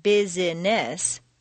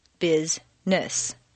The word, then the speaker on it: business: For example, business is not business, but rather business.